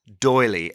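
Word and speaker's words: D'Oyly: he added Doily, and it's spelled D-apostrophe-O-Y-L-Y.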